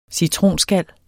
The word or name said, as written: citronskal